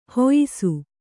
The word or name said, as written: ಹುಯ್ಸು